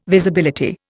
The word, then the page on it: Visibility: Indian